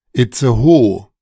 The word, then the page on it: Itzehoe: German